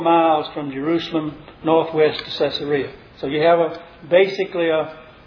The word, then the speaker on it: Caesarea: I know everybody pronounces that Caesarea, but it's named after Caesar, not Caesar.